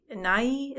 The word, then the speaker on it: Nighy: Nighy, is that how you say his name? – Oh, yeah.